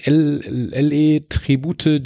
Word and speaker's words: LE Tribute: wie spricht man den aus? Und eigentlich sagten sie, ja, würde man LE Tribute Gin, kommt eigentlich aus Spanien, ist eine dieser ähm, Zitrus-Aroma-Bomben sozusagen und ähm, einfach eine ganz tolle Flasche.